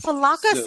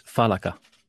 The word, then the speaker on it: falaca: It's not falaca, they pronounce it differently. We pronounce it differently. – Oh, please pronounce it correctly, since you're the first person who would be able to. – Please. – So the correct pronunciation is falaca. – Okay. – Good to know. I will never screw that up again. – Falaca. – That's correct.